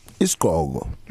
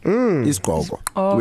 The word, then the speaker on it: isigqoko: So I'm going to spell it two different ways, and you know why I need to spell. So Isigqoko. – Yes. – And then it's also Isigqoko without the second I. – Is that how we pronounce it? – How we pronounce it, yes. So you would have an apostrophe where you'd have the other I. – Yes. – So isigqoko, but when people speak, they say isigqoko.